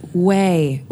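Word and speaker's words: Ouai: I know, but it's like O-U-O-I. – Oh, yeah. – It doesn't look like that, but it's pronounced Way. – Not we. – No, it's Way.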